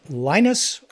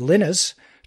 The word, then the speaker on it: Linus: Uh, his name was Linus, or as he pronounces it, Linus Torvalds, and it, it was a version or a rewritten version, if you will, of Unix.